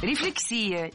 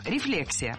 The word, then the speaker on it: рефлексия: Рефлексия. Неверно. В этом слове ударение должно падать на второй слог. Рефлексия. Для запоминания предлагаю однокоренное существительное с непроблемным ударением. Рефлекс. Рефлекс. Рефлексия. Запомнить легко, и пора бы уже это сделать.